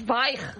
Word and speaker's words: Zweig: Tú sabes que yo toda mi vida he estado diciendo que yo leía Stephen Zweig. – Ah, claro. Eso no. Es Zweig.